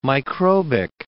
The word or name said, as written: microbic